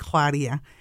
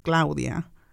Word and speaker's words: Claudia: And even saying my name in Spanish, saying Mexico in Spanish. When I introduced myself, I used to be like Claudia, and now it's Claudia. That's my name, and that's how you pronounce my name.